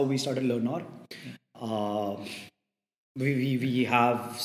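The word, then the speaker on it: Learnaur: we we are also co-founders at a content creation company called Learnor where it's it's all about learning. It's spelled as learn, L E A R N A U R R. Uh, the Hindi or means for more. So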